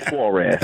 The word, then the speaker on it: Suarez: Okay, you want me to say it right? Suarez. Are y'all happy? – Suarez, that's him.